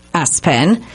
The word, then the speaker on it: ESPN: Lester, probably because it rhymes with, the name was ESPN, pronounced S-Pen.